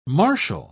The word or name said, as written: martial